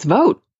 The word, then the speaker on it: Vogt: How do you pronounce I think it's Vogt. – That's what I thought.